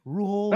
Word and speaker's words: Rhule: Did I even pronounce the last name right? Rhule? – No, you pronounced the name right.